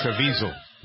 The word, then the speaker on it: Caviezel: Person of Interest with Jim, I can never say his name right, the guy that played Jesus in the Caviezel. – Caviezel. Right, yeah.